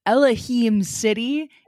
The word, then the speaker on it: Elohim City: Is it Elohim City? Is that how you pronounce it? – Yes. Okay.